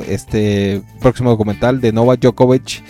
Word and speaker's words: Djokovic: Y en este caso hablamos de Djokovic. – Así se pronuncia.